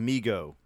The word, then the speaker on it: Mego: I think it's pronounced Mego.